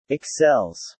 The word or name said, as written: excels